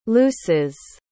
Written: looses

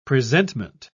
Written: presentment

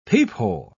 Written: peephole

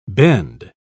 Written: bend

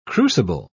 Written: crucible

